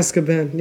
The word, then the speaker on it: Azkaban: Third one, Prisoner of Azkaban. Am I saying it right? – Azkaban, yeah.